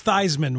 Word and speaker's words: Theismann: Reminds maybe not quite as selfish as Theismann, which rhymes with Heisman. Dude, he changed his name to rhyme with the award.